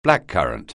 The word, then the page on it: blackcurrant: anglais